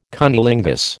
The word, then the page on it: cunnilingus: American English